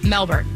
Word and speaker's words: Melbourne: Melbourne. Or Melbourne. Oh, Melbourne. Apparently they don't pronounce the R.